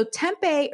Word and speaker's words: tempeh: So that is spelled T-E-M-P-E-H, but it's pronounced tempeh.